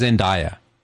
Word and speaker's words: Zendaya: She says it's Zendaya. Like Eric Dyer.